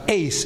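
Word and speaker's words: ace (εἰς): There are other prepositions, for example, eis, E-I-S, sometimes pronounced ace in the Greek, and that's translated as into.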